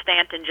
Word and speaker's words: Staunton: He was born in a little town that looks like it should be pronounced Staunton, S-T-A-U-N-T-O-N, but it's pronounced Stanton, just very near where I'm sitting here in Charlottesville.